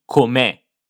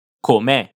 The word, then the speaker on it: com'è: Attenzione alla pronuncia di com'è. Abbiamo un accento alla fine, quindi dobbiamo farlo sentire. Com'è?